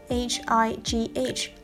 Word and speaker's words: high: H I G H high 高的，它和平常打招呼用的 hi 是相同的读音。